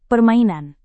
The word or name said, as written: permainan